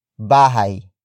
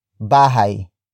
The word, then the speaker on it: bahay: And that is bahay. Bahay. Bahay. Bahay is spelled as B-A-H-A-Y.